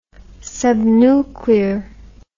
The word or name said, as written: subnuclear